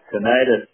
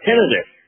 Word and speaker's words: tinnitus: Yeah, tinnitus or tinnitus, as they say. Yes, which is the proper way to say it, I believe.